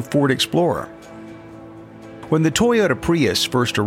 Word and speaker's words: Prius: Prius.